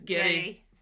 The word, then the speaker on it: Geddy: His grandmother, I think, in her heavy Canadian accent, used to pronounce it as Geddy.